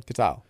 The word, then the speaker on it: Katyal: We'll call it the Neil Katyal Raptors. No, is it, how's it pronounced the last name? – Katyal. – Katyal.